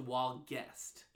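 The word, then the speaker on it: Walguest: Well, my compassion child is named Walgist. – What? – Yeah. It's August? No, it's... W-A-L-G-U-E-S-T. So, Walgest. – You know what? That sounds exactly how it's spelled.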